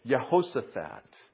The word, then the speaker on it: Jehoshaphat: Another J there that's pronounced as a Y. Jehoshaphat.